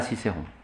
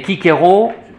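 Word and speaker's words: Cicero: Vous demanderez à Cicéron. Mais Kikero et Kekidi Kikero, il parlait peut-être la prononciation restituée.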